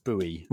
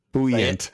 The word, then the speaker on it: buoy: So in British English, that is buoy. It's always been buoy. Like, you know, what is the property of being able to float? It is buoyant.